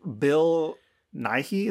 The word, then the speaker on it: Bill Nighy: Literally, Bill Nighy, is that how you say his name? – Yep.